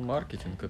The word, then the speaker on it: маркетинг: Я бы сказал, маркетинг это английское слово, у ну, них ударение а на первый слог.